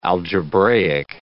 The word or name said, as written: algebraic